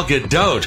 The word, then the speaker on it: Gadot: Just ask Wonder Woman Gal Gadot, whose last name was among the most mispronounced words of 2017. Yep, it turns out you do pronounce that T after all.